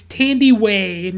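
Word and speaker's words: Thandiwe: It's T-H-A-N-D-I-W-E. And it's like, I had always seen it, like, misspelled without the W, and I just thought it was Tandy. But, like, it's Tandiway Newton.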